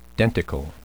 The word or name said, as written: denticle